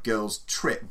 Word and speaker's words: Girls Trip: Yeah, needless to say, I don't think he's produced anything quite like Girls Trip, but we'll get into that. This, yeah, stress on the second word.